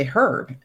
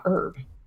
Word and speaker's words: herb: And I have to mention that in England and Canada, you say herb and not herb. – Yeah. – So if you're in the US and you hear Kurt saying herb, that actually is a correct pronunciation there.